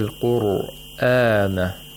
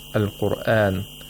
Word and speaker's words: al-Qur'an: Ra is pronounced with tafkhim in this word, al-Qur'an, because it's sakin and preceded by a letter with a dhamma. So you have to pronounce it thickly with velarization. Al-Qur'an, with a full mouth.